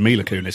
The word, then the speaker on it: Mila Kunis: I said Mila Kunis because that's how you pronounce it.